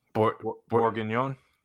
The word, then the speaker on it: bourguignon: The beef bourguignon? – Yes.